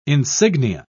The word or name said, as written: insignia